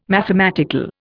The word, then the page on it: mathematical: Indian